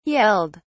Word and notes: yelled: British English

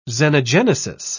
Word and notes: xenogenesis: British English

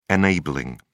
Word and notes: enabling: British English

